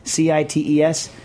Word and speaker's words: CITES: It's CITES? Is that how you pronounce it? CITES? – Yeah.